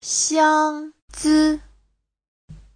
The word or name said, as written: xiang1zi5